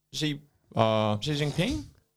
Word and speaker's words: Xi Jinping: Xi Jinping? Is that how you say it? – Yeah,